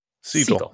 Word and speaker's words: Segel: Jason Segel? – Siegel. It's Siegel.